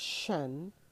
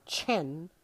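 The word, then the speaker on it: CHEN (חן): In strong, the word grace there in Genesis chapter 6 and verse 8 is translated as shen or chen, or maybe it's hen. It's CHEN, and it is translated favor, grace, acceptance.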